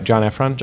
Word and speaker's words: Heffron: I'm saying that right, John Heffron? – John Heffron, yeah. Heffron.